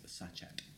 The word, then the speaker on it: sachet: Which I believe is actually pronounced sachet.